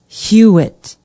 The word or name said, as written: hewett